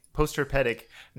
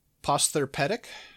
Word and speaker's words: postherpetic: Postherpetic neuralgia. Oh, maybe posther instead of poster.